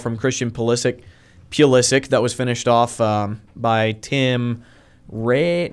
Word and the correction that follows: Weah: I can't remember how you say his name. – Weah. – Thank you. I knew it was like Raya or something like that. Weah.